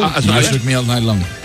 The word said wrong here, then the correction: Shook Me All Night Long: Lo has dicho en francés. – O sea, has cambiado cuatro palabras de sitio. – Has engolado tanto que se te ha pasado de idioma. – Qué grande, tío. – Me cuesta el Shook Me All Night Long. – Ahí mucho mejor. Muy bien. Parece un plato un tailandés, ¿eh?